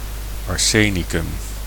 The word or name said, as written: arsenic